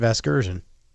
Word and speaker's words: Vasgersian: Isn't his last name Vergasian? – You know who that is. – That would be my pal Matt Vasgersian.